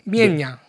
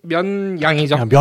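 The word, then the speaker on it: 沔阳: 그건 중국 발음인가 보죠? – 미엔양. – 미엔양. – 네. – 네네네. – 면양이죠. – 면양. 면양현. 네.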